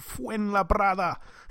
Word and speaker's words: Fuenlabrada: Okay, this one is at the Polideportivo Fernando Martin in a place called Fuenlabrada.